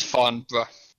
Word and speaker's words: Farnborough: It's Farnborough. British people don't pronounce half their vowels. It's Farnborough.